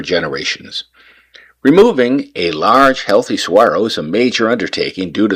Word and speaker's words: saguaro: First, pronunciation, the go, is silent, sounding like a WH. It's said, saguaro, a word originating from indigenous peoples of the Sonoran Desert, meaning giant tree-like cactus.